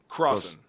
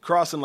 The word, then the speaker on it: Crossin: And Iman, you're going to have to help me with the pronunciation of your last name because I think it's Crossin. – Yeah. – Crossin like crossing the street.